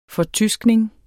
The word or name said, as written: fortyskning